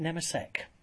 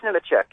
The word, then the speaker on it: Nemechek: I just want to confirm: is the pronunciation correct for Nemechek or is it Nemesek? – It's Nemechek. – It is Nemechek.